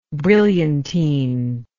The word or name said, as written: brilliantined